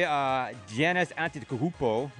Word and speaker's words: Giannis Antetokounmpo: Your boy Giannis Antetokounmpo.